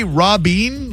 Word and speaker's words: Robbie: Hi, my name's Robbie. How are you? – I'm good. Did you say Robbien? Like R-O-B-Y-N, but pronounced Robbien? – No. No, Robbie.